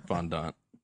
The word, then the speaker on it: fondant: I don't think I've ever had fondant. – Fondant? – I'm saying fondant. – Fondant. – I'm just going to passive-aggressively correct your pronunciation.